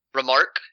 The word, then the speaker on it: Remarque: Eric Marla Remar- R E M A R Q U E. Remark? – Remark, Erich Maria Remarque.